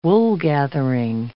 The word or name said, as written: woolgathering